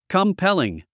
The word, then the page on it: compelling: kəmpéliŋ; コンペリン